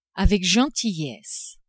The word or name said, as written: avec gentillesse